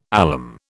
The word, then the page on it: alum: British English